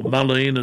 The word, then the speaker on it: mullein: In this particular case, the herb that you are taking, mullein or mullein as some pronounce it, M-U-L-L-E-I-N,